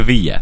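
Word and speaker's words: villa: Δεν είναι Βίλη, είναι Βίλια. – Βίλια. – Σεβίλια γράφεται. – Ναι. – Απλά προφέρεται Ιά γιατί είναι double L στην Ισπανία.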